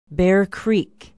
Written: bear creek

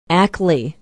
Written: ackley

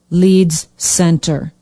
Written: Leeds Center

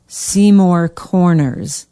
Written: Seymour Corners

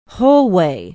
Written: holway

